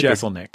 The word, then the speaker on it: Jeselnik: Well, Anthony, does he pronounce his last name Jeselnik? – Jeselnik.